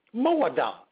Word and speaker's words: moada: Moada is the Hebrew word pronunciation for seasons.